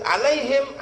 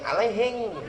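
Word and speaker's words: alaihim (عليهم): Orang Bugis kalau N jadi Eng, kalau Eng jadi N. Di turun Bugis, alaihim, alaihing. Surathal ladzina an'amta 'alaihim surathal ladzina an'amta 'alaihim surathal ladzina an'amta 'alaihim surathal ladzina an'amta 'alaihim minkum surathal ladzina an'amta 'alaihim.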